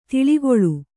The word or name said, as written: ಧಾಳಿಗೊಳ್